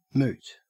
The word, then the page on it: moot: Australian English